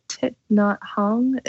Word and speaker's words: Thich Nhat Hanh: Is it Thich Nhat Hanh? Is that how you say his name? – That's right.